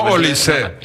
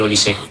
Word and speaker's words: Olise: o como Kone o como Kanté, entonces me extrañaba por eso que no se pronunciara la E de Olise.